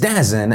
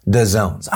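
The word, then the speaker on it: DAZN: I pronounce this Dazin, and those of you in the comments section who were able to use your fingers after you cringe so hard that really it almost put you in the hospital, you informed me that it's actually pronounced DAZONES.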